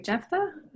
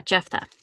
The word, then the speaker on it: Jephthah: Jephthah? Is that how you pronounce that? – Yeah, Jephthah.